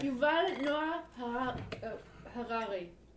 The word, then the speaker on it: Yuval Noah Harari: Yuval Noah Harari.